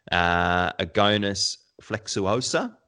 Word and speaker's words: Agonis flexuosa: I've also used this technique on an advanced Agonis flexuosa. Did I say that right? – Oh, very good.